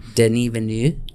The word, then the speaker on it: Denis Villeneuve: Denis Villeneuve. It's spelled Dennis, but it's pronounced Denis.